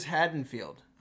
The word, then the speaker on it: Haddonfield: So, last week, as we I said let's go to Haddonfield, and apparently, as you guys have both correctly pronounced it this way, it is Haddonfield. I've been saying it wrong for 15 years now.